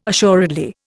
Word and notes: assuredly: Indian

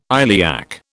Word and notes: iliac: British English

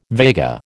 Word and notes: Vega: American English

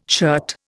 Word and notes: shirt: Indian

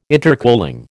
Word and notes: intercooling: American English

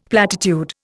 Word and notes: platitude: Indian

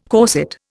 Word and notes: corset: Indian

